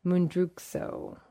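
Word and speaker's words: Mundruczó: I'm probably butchering that. Yes, butchering that name. Mundruczo. I think that's how we would say it. It's a Hungarian name.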